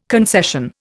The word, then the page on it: concession: Indian